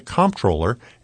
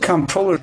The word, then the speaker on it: comptroller: For the first time in 20 years, New York State's comptroller Tom DiNapoli is facing challengers in this year's primary on June 23rd. WAER's Bryson Pace takes a closer look at what the comptroller actually does.